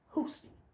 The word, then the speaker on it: Hosty: Hosty. Rhymes with toasty.